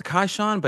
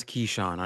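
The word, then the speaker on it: Kyshawn: So it's spelled Kaishawn, but everyone says Keyshawn on his team.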